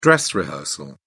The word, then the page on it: dress rehearsal: British English